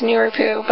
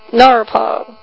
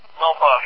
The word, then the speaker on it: Nurupo: all right, I've been pronouncing this Nuripu, but it's actually pronounced Norpo, right? – Norpo, yep.